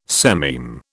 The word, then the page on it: sememe: British English